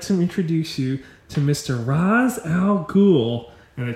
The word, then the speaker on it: Ra's al Ghul: Especially the scene where my favorite part of that movie is the scene where he's at the mansion and technically you're supposed to pronounce it Ra's al Ghul, but it's Ra's al Ghul in the movie.